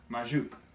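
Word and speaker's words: Majok: Oh, I think I found the pronunciation. – Majuk? – Majuk.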